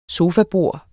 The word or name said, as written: sofabord